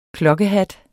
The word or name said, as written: klokkehat